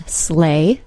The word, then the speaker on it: sleigh: The word sleigh, S-L-E-I-G-H, does actually have a pronunciation we can predict, because words spelled E-I-G-H are usually pronounced with a long A. Other examples are the number 8 and the word way, spelled W-E-I-G-H.